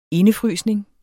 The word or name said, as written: indefrysning